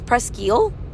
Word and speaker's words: Presque Isle: And anyway, the last 4 miles of this marathon are on, you could say Presque Isle or I actually heard it's Presque Isle. So apologies if I said it wrong when I said Presque Isle,